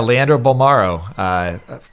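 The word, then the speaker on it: Leandro Bolmaro: Did I pronounce his name right? – Yeah, Leandro Balmaro plays in Barcelona out of Argentina.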